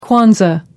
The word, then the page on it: kwanzaa: American English